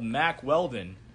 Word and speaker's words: Mack Weldon: It rhymes with Wack Speldon. – Jack Feldon. – Yeah. – Is it? – No. It's called Mack Weldon.